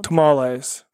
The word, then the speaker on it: Tamales: Tamales.